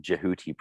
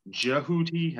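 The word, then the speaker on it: Djehuti: You prefer the Jehuti pronunciation, correct? – It just kind of depends. Jehuti has a nicer sort of mouth feel.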